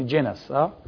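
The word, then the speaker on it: genus: الجنس، الجنس، جنس، جينوس بالألماني، باللاتيني جينس، تلفظ جينس، جينس اللي هي الجنس.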